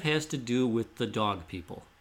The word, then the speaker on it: Doeg: Yeah, in the records, that's how it's D-O-E-G, but pronounced dog, named after Dog Island.